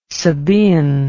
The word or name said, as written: Sabean